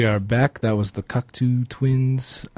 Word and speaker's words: Cocteau: I bet it's Cocteau Twins. Because, like, plateau.